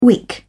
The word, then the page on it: wick: British English